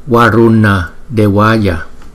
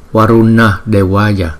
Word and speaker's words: Varuna Devaya: Thì Thủy Tiên Phật chúng ta nhìn thấy là Varuna Devaya, Varuna Devaya. Chữ N nè, chúng ta phải cong lưỡi lại và lấy cái hơi từ trên vòm miệng chúng ta.